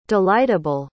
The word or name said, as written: delightable